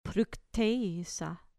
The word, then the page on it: pruchtheisa: grec